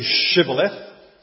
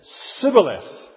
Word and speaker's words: shibboleth: Say shibboleth. And they couldn't pronounce it properly. The Ephraimites, for whatever reason, couldn't say shibboleth. They said sibboleth.